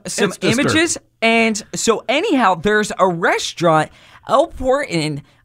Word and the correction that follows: Portón: on Poplar Avenue. Did I get that right, Josh? – Think he just butchered that. – Yeah, it's Portón, but I did.